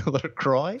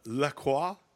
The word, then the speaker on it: La Croix: Could be a LaCroix or a La Crox or whatever it's called. – La Croix. As we know, it says explicitly on their website, it's like rhymes with enjoy, LaCroix.